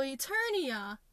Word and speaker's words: Eternia: Eternia is He-Man land. Maybe? Oh, crap. Yeah, I'm saying it right. But yeah, so Eternia, that is a planet that is supposed to be filled with humanoid aliens.